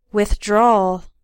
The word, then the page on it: withdrawal: American English